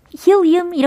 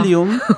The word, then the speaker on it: helium: 네, 근데 이게 헬륨이라는 단어가 히오이음 이렇게 발음이 되는 거네요. 그죠? – 네.